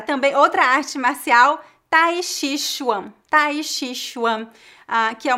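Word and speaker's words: Tai Chi Chuan (太极拳): Tai Chi Chuan. É T-A-I. – Tai Chi Chuan. – J-I. A segunda sílaba não é C-H-I, e sim J-I. E a terceira sílaba é Q-U-A-N, não é C-H-U-A-N.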